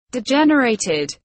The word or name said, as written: degenerated